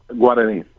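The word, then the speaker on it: Guaraní: The pronunciation is Guarani? – Guarani, right. Okay.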